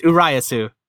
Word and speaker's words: Urayasu: I'm just looking at, like, so I'm going to pronounce this wrong. – It's Urayasu. – Urayasu. Okay, yes, awesome.